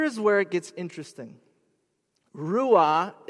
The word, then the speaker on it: ruah: And the Hebrew word for it is ruah, R-U-A is how we'd spell it, ruah.